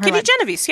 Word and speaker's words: Genovese: I think also of, like, the Kitty Genovese case. – Oh, sure. – I forget how you pronounce her name. – Genovese, yeah.